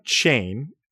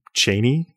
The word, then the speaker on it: Cheyne: So I was surprised to learn that John Chain, as we have come to pronounce his name in America, likely would have pronounced his name Cheney in Scotland, because I think that's just how his name is pronounced, actually.